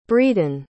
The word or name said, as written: bredon